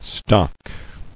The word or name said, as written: Stocker